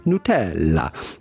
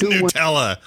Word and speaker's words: Nutella: Of course, you can always say Nutella, but with the typical Italian pronunciation, how would you say it in Italy? Well, that would be Nutella. Nutella. – It is Nutella.